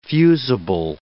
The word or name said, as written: fusible